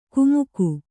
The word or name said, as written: ಕುಮುಕು